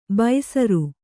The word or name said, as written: ಬೈಸರು